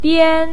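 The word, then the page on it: bian1: tone 1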